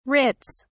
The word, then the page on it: writs: British English